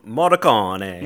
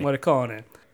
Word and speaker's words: Morricone: Morricone. – Morricone.